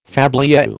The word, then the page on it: fabliau: American English